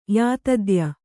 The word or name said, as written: ಯಾತದ್ಯ